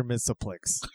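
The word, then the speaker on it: Mxyzptlk: A-N-T-H-O-N-Y space I-S space A space D-U-M-B-A-S-S space. Mr. Mixelplix.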